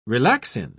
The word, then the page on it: relaxin: British English